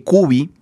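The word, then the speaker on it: Kuby: Les recomiendo La Revolución Sexual Global de Gabriele Cubi.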